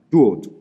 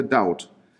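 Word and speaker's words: Dod (דוד): Auf Englisch schreibt man ihn als David, aber die hebräische Aussprache von David lautet Dod oder Daud.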